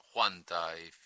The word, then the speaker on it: Huanta: And next, we've got in Peru, Radio Huanta, or Huanta, if you prefer to pronounce that H, but you don't in Spanish. So it's Radio Huanta with an initial H.